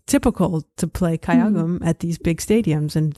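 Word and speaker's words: kayagum (가야금): Yes, kayagum.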